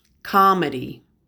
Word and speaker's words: comedy: This next short list of words have a stress COM, and the stress sound sounds like calm. It's a short O sound, ah. Comedy, comment, commerce, common.